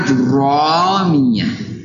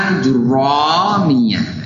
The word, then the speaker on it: Ajurrumiyyah: Ya, jadi antum sekarang bacanya harus dengan benar. Ajurramiyah. Ya. Sambil begini. Jadi untuk melatih lisan antum untuk mengatakan dengan benar. Ajurramiyah.